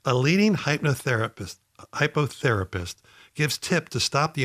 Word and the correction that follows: hypnotherapist: But it's pronounced hypnotherapist.